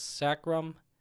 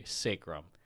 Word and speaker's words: sacrum: I mispronounced it as sacrum. So the actual terminology is, or the pronunciation should be sacrum.